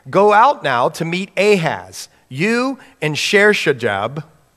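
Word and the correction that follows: Shear-jashub: I don't even know how to say it, Sher-Jashub, sorry about that,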